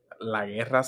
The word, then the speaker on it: La Guerra: La Guerra Civil. – La Guerra. I cannot do La Guerra. – You gotta roll your R's.